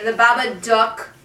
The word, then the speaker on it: Babadook: But anyway, the Babadook. – Duck. – Because it rhymes with book and look is...